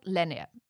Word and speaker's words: Lendager: They are called Lenier. And this is very Danish. If you want to look them up, it's spelled L-E-N-D-A-G-E-R.